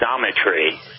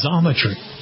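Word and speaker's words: Xometry: And is that the correct pronunciation of your company? – It's pronounced XOMtry. So, XOMtry.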